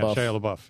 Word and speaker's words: Shia LaBeouf: Yeah, Shia LaBeouf, yeah. – He's in it, and then an underrated actor, in my opinion. – Oh, absolutely. – Shia LaBeouf is good.